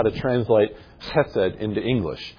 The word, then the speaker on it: chesed: If you're going to pronounce it properly in Hebrew, you've got to go, chesed. It's this word chesed.